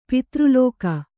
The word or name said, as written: ಪತ್ರಲೇಖೆ